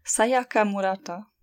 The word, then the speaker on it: Sayaka Murata: Sayaka Murata.